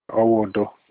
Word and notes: owudo: Olukumi

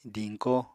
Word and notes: diñko: apatani

